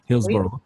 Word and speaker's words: Hillsborough: Il y a la fougue de la fée, puis il y a 47 euh, Uppers euh, Hillsborough. j'espère que je le prononce bien, Hillsboro.